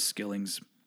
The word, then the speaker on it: Skillings: did I just call him Muzz Skullings? I meant Muzz Skillings.